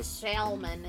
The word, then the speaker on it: salmon: That's just how folks say salmon instead of salmon. – Well, that's the French pronunciation. – Yeah, but salmon is leaving the L that's actually there out. – Yeah. – But there is an L.